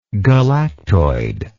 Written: galactoid

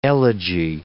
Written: elegy

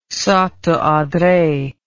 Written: Santo Andre

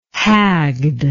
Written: hagged

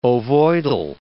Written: ovoidal

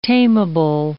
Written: tameable

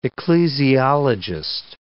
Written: ecclesiologist

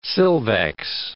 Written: silvex

